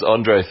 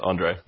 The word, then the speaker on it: Andrzej: My name's Andre Fenner, but it's a Polish name, so it's actually Andre spelled A-N-D-R-Z-E-J.